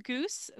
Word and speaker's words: Goos: I am at Bex Goos. That's BEXGOOS. Don't put an E at the end of my name. It's pronounced like the bird.